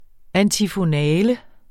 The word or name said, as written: antifonale